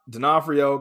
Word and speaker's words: D'Onofrio: And then, we have D'Onofrio coming back, reportedly.